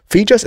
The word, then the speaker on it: Fejos: Fijos, it's spelled F-E-J-O-S.